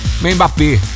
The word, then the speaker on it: Mbappé: Em partidas válidas pelo Grupo I, a França, com 2 gols de Mbappé, eu nunca sei falar o nome dele, né? Fez 3-1 em cima de Senegal. Mbappé, pronto, falei certo agora.